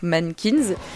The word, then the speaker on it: MNNQNS: En effet, nos régions du talent, puisque cette semaine, je vais vous parler du groupe Mannequins, qui s'écrit pas comme ça se prononce, puisque ça s'écrit M-N-N-Q-N-S, voilà.